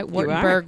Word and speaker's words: Wartenberg: And then I got two Wartenberg, I'm saying that right, Wartenberg wheels.